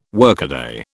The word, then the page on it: workaday: British English